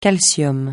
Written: calcium

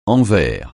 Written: envers

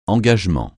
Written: engagement

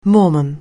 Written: Mormon

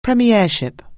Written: premiership

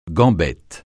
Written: gambette

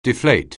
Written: deflate

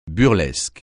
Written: burlesque

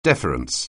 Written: deference

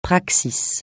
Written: praxis

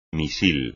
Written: misil

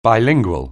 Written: bilingual

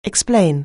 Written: explain

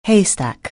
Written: haystack

